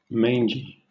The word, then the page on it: mangy: British English